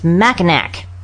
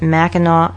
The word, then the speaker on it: Mackinac: Now, if you were to look it up on the internet and see it spelled, you'd say, oh, it's Mackinac. But that's how it's written. – That's how a Joycean says it. – But no, actually it is pronounced Mackinac Island.